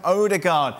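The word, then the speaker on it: Ødegaard: Meanwhile, Arsenal confirmed Martin Odegaard. – Have you said that correctly? – Yeah, has made that loan move to the Gunners, moving from Real Madrid.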